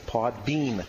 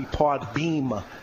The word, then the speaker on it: Podbean: Podbean. – Very good. – Yes. – I'm so proud of you. – Because it sounds like it should be pod beam, but it's bean like.